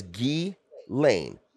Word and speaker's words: Ghislaine: Her name is Ghislaine. Ghislaine Maxwell. Yeah. Say it. – Ghislaine? Say it. – Yeah, fuck it. Ghislaine.